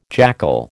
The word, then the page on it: jackal: American English